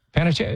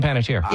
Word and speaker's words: Panettiere: You know, I'm listening right now to the Hayden Panettiere audiobook. Did I say it right? – Panettiere. I say Hayden Panettiere.